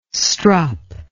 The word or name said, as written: strop